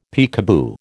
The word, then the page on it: peekaboo: American English